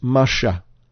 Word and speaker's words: Masha: It comes from the word Mem Shin He, pronounced Masha.